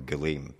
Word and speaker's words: Gullaime: is the I'm going to pronounce this wrong, the Gullaime. – That's right, that's how I do it.